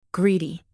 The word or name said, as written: greedy